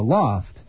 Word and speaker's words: L0pht: A group of Boston-based sophisticated computer hackers called The Loft, pronounced loft because it's not spelled that way. It's spelled L0PHT.